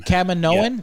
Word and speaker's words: Kaminoan: It looks like the last episode of The Bad Batch saw the Galactic Empire wipe out all of the, how do we say this, Kamonans? The Kamonan facility, cloning facilities during the attack. – Kaminoan. – Yeah.